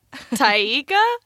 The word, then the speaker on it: Taika: How'd you say Taika just then? – Taika. – Taika? – I think it's just Taika Waititi.